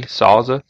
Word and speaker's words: Sauza: Is that how you pronounce that? S-A-U-Z-A? Sauza? – That's how Sauza. You pronounce it, yeah.